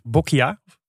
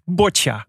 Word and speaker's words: boccia: Ik dacht dat het is Bokia. Maar het is dus boccia. Boccia. Boccia. Ja, en het is de, wat het dus is, een, een balsport of een werpsport.